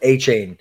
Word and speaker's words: Achane: I was hoping Cody correct me, but Achane, yes.